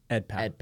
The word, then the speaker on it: Edpao: Edpao.